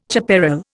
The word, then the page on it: chaparral: Indian